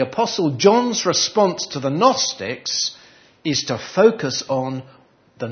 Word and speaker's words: Gnostics: They're known as the Gnostics. You don't really pronounce the G, but I did, so you can recognise the spelling there. So it's like Gnu.